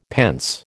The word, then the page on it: pence: American English